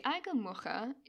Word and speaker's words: Aigamuxa: So, the Aiga Mocha is a fearsome desert beast. And just by the way, this is spelled A-I-G-A-M-U-X-A. So I had to look up X means there.